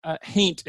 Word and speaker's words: haint: And you talked about the big beastly haint, and the for listeners, haint is spelled like the word ain't, but with an H in the front.